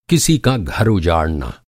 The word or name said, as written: उजाड़ना